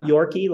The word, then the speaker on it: York.ie: It's not Yorkie like the dog. – Even though it's spelled that way, right? – Like exactly. – York.ie, yeah. Yorkie, yeah.